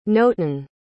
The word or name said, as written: notin